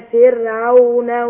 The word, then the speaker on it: فرعون: واغرقنا آل فرعون وانتم تنظرون. – جميل، يبقى كلمة فرعون تنطقها كده على طول اللي هي الراء هنا مرققة.